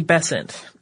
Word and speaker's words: Besant: In 1888, the Bryant and May match factories came to the attention of Annie Besant. Most American pronunciations of this seem to rhyme with Crescent, so we're going with that.